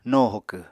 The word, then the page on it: no hokw: Apatani